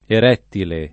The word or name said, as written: erettile